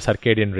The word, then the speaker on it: circadian: or your circadian rhythm, which is when you sleep and you wake up.